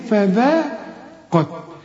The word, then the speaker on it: فذاقت: هنا برضو هنقول إيه فذاقت، القاف هنا المفخمة فقط.